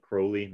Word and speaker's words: Crowley: And so, you know, like I mentioned, I got that Rigardi book, and then eventually got some of Crowley, whatever you want to say, right? – If you think me holy, if you think me holy, call me Crowley.